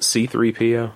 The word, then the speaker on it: C-3PO: I was 3CPO. – Isn't it C-3PO? – I don't know. When I was a kid, I said it wrong.